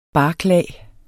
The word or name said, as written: barklag